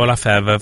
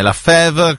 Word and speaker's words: La Fèvre: La Fèvre, con un bel accento grave sulla prima E. La Fèvre.